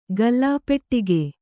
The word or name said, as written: ಗಲ್ಲಾಪೆಟ್ಟಿಗೆ